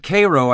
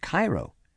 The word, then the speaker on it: Cairo: At Cairo, I stopped for gas, and in fact I did ask the old guy who dotted out to fill my tank why they pronounced Cairo as they did. "Because that's its name," he explained, as if I were kind of stupid. "But the one in Egypt is pronounced Cairo." – "So I've heard," agreed the man. – "And most people, when they see the name, think Cairo, don't they?" – "Not in Cairo, they don't," he said a little hotly.